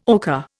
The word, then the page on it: ocher: Indian